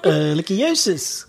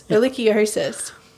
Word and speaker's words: Ehrlichiosis: Ehrlichiosis. – Ehrlichiosis.